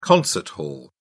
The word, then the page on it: concert hall: British English